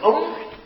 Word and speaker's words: Án: Và chữ Án đúng ra là phải đọc là chữ Úng, nếu đọc chữ Phạn là Úng.